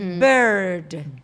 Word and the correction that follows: beard: Beard, pero se pronuncia beard.